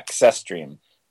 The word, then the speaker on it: Sestrim: Key to the backstory are these Sestrim, I would say. Is that how you say it? – I said Sestrim, yeah. Sestrim. I get like a K and an S sound in there. – Okay, Sestrim.